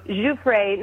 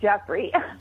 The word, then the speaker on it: Giuffre: It's all out there, but I just wanted to make sure you knew her name is, you know, Giuffre, not Jeffrey. Don't say that.